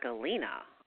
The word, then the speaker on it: galena: But I didn't know much about galena. I was like, did I say it correctly? – You did, and galena is lead.